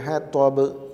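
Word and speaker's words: ഹതബ് (حطب): വംറഅത്തുഹു ഹമ്മാലത്തൽ ഹതബ് എന്നുച്ചരിച്ചാൽ അത് തെറ്റായിപ്പോയി. ഹതബ് ചുണ്ടിന് അനക്കം പറ്റാതെ, ചുണ്ടിളകാതെ തന്നെ ത എന്ന് ഉച്ചരിക്കാൻ നന്നായി പരിശീലിക്കുക തന്നെ വേണം.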